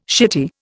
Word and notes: shitty: Indian

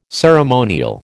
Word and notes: ceremonial: American English

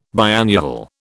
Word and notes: biannual: British English

